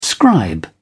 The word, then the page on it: scribe: British English